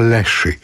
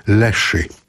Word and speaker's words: Leshy: Spodobał się Państwu zespół z Płocka, który się nazywa Leszy. Pisze się L-E-S-H-Y. Leszy.